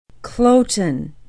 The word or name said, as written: kloten